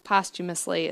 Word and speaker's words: posthumously: No, posthumously is how it's pronounced. – I think it's pronounced posthumously. – You spell it posthumously, but it's pronounced posthumously.